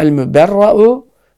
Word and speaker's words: el-müberra'u: Daha el-müberra'u, müberra deniyor. O hemze, okusun el-müberra'u tabi okunuyor buradan. O hemze elife döner yani müberra.